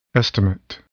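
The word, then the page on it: estimate: noun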